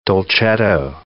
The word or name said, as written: dolcetto